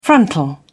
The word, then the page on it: frontal: British English